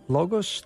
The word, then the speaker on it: Logos: Logos? Logos? What is the proper pronunciation, Michael? – Well, you'll hear both. – Okay, good. I don't feel so bad. – Logos or Logos.